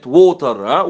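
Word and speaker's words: water: في النطق الأمريكي بالمناسبة لو التي وقبلها فاول واو وبعدها فاول ولا ينطبق، لا ينطبق هنا على زي كلمة ووتر، ووتر ووتر يعني يروي، ووتر اللي هو المية، هم تنطق شبيهة بالدي، ووتر ليتر ليتر اللي هي كلمه ليتر، اوكي.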